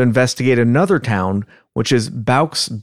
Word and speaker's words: bauxite: From what I understand, I heard it pronounced as bauxite.